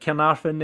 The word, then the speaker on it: Carnarvon: I mean, you've just come off the back of a victory against Carnarvon. Is that how you pronounce it? Carnarvon. There you go.